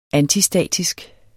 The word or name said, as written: antistatisk